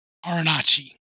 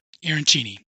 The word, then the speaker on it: Arancini: This egg roll versus Arancini. – Arancini. – There we go again. I didn't say it right the first time.